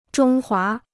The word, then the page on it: 中华: zhōng huá